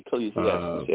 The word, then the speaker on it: Ecclesiastes: and I just wanted to share Ecclesiastes. I hope I said that correctly. – Yeah. Ecclesiastes, yeah.